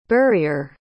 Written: burrier

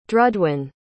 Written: drudwen

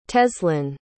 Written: teslin

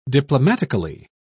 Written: diplomatically

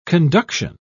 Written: conduction

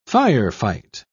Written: firefight

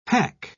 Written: hack

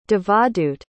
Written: Devadut

